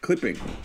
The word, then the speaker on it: clipping: But no, look, really, there's like one actual rapper that, like, clipping.